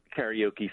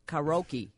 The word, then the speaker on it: karaoke: Isn't it actually pronounced karaoke? – It is, isn't it? – I mean, because when it first came here and became popular, everyone would say karaoke. – Right, right. – So it's karaoke now? Is that the Americanized pronunciation? What's the right way to say it, Rob?